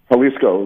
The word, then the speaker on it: Jalisco: Tell us about the Jalisco Boys. Am I pronouncing that correctly? – Jalisco, right, correct.